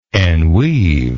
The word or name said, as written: enweave